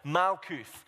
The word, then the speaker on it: Malkuth: One is Old Testament Hebrew, and it's this word, Malkuth.